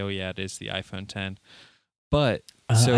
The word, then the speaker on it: iPhone X: Because they got on stage and said it's pronounced the iPhone 10 on stage.